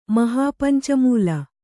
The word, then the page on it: ಮಹಾಪಂಚಮೂಲ: mahāpanca mūla